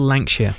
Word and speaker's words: Lankshear: Will Lankshear. – Yeah, not like Guy from Norton's Wife's Maid, who thought he was called Ray Lampshade. – So definitely not Lampshade.